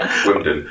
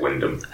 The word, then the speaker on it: Wyndham: Wimden. – Is that right? – No, I say Wyndham. – I say Wyndham. – Yeah. – Normally. – Even though it's with an M.